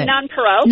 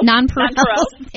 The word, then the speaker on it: nonpareil: And if you need, what, I think, how do you pronounce it? – Oh, nonpareil. – Nonpareil. Thank you.